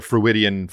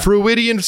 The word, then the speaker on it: Freudian: Freudian, like Sigmund fucking Freud.